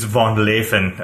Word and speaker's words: Van Leeuwen: my family's approach to pronouncing that name is different than the Dutch way of pronouncing the name. – What's the Dutch way of pronouncing it? I don't know exactly because I'm only Dutch in last name only. But I believe it's Van Leeuwen or something.